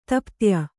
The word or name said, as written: ತಪ್ತ್ಯ